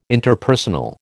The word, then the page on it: Interpersonal: American English